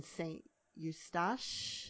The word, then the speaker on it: St. Eustache: And here's another one where you're talking about one of the organ pieces in St. Eustache. Is that how you pronounce it? – That is so exactly right.